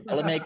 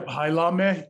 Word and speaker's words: Hailame: Hailame Kinikini.